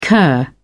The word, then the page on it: kir: British English